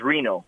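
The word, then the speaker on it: Rino: Well, my middle name is Frank, but my first name is Reno.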